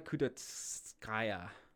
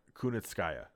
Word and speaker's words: Kunitskaya: With Yana Kudatskaya. – Kunitskaya.